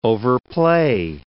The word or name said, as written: overplay